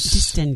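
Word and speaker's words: Distingué: A lovely record from Songs for Distingue Lovers. – Distingue.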